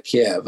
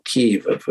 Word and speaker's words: Kyiv: And this column that's approaching Kiev, I mean, I'm sure, or Kiev, as we now should call it, using the Ukrainian pronunciation, it doesn't look as to me as though, you know, it's stoppable, however courageous the Ukrainians are.